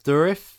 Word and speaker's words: Dourif: Did we work out how to say his name? – Dourif? – Let's say that.